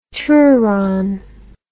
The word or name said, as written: touron